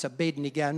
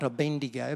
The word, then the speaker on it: Abednego: You recall a time when Shadrach, Meshach and Abednego, you've got to get his name right, it's Abednego, not Abendigo.